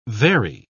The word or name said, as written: vary